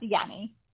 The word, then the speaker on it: Jannie: Yeah, it's a J, but yes, pronounced Yanny.